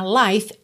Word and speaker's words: life: Life, L-I-F-E, esse I também é o I longo aberto, com som de ditongo, aí, tá? Life, termina no som do F e o E final não é pronunciado, tá? Vida, life. Curiosidade extra, o plural de life é lives, com V, ok? Lives, L-I-V-E-S.